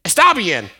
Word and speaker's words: está bien: Está bien? Anybody Spanish speaking? Está bien? Está bien. Yes, that's the pronunciation.